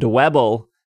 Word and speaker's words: Dwebble: Bug-type Pokemon, including Scyther, Venipede, and Dwebble, because it rhymes with Pebble,